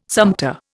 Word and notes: sumpter: Indian

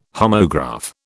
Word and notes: homograph: British English

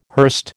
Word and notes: hurst: American English

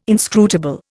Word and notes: inscrutable: Indian

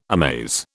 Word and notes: amaze: British English

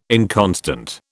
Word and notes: inconstant: British English

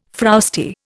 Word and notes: frowsty: Indian

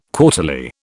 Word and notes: quarterly: British English

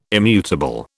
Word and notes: immutable: British English

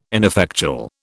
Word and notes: ineffectual: British English

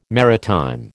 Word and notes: maritime: American English

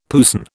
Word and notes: puisne: British English

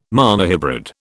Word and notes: monohybrid: British English

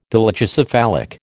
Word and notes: dolichocephalic: American English

